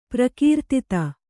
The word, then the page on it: ಪ್ರಕೀರ್ತಿತ: prakīrtita